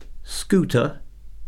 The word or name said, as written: scooter